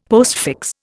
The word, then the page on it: postfix: Indian